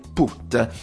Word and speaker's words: Puth: Questa è Charlie Puth, proprio lo disse lui appena uscito in un'intervista italiana che gli fecero, volevano capire come si pronunciava, se Put o Puff, come solitamente dovrebbe essere, e lui ha detto: io mi chiamo Charlie Puth.